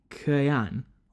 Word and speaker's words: Khagan: Whereas the second title, Khagan, or if we're really trying to pronounce it totally correctly, Khayan, or Khagan, means essentially Khan of Khans, or Great Khan, the overall ruler of a Khaganate.